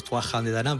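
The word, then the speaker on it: Tuatha Dé Danann: Los Tuatha de Danann son los, bueno, los irlandeses los pronuncian diferente, los Tuathan de Danann, pero sí, para nosotros los Tuatha de Danann, pues ellos realmente eran el penúltimo pueblo mitológico que habitó aquí la isla de Irlanda.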